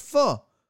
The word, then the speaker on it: pho: P H U poo. They're like, bro, that's pho.